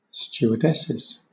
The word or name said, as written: stewardesses